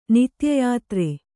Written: ನಿತ್ಯಯಾತ್ರೆ

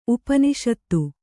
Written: ಉಪನಿಷತ್ತು

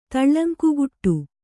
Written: ತುಳುಂಕುಗುಟ್ಟು

